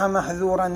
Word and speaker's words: محذورا: يعني هنا يريد أن يشير يجب على القارئ أن ينطق الذال من كلمة محذورا والسين من كلمة عسى مع مراعاة صفة الانفتاح فيهما، فإن لم يراعي الانفتاح ونطقهما بالإطباق تحولت الذال إلى ظاء والسين إلى صاد فتصيران محظورا بدل محذورا وعصى بدل عسى.